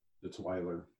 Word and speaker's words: Weiler: I hope I said that right. Weiler, is that correct? – No, it's Weiler. – Weiler, okay, I'm sorry.